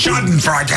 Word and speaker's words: Schadenfreude: But you got to say it like you're German. Speaking to 10,000 Nazis. Like, Schadenfreude. – Yeah, like you're angry. – You with some authority.